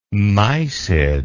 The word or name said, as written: mysid